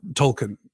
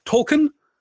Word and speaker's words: Tolkien: There are two accepted pronunciations, J.R.R. Tolkien and J.R.R. Tolkien. Technically, it should be Tolkien because it is of a Germanic root, and as the professor himself would tell us, therefore pronounced Tolkien.